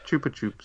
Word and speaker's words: Chupa Chups: Chupa Chups is actually pronounced Chupa Chups.